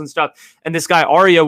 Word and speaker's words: Ariea: And his name is Aria. It's spelled A-R-I-E-A.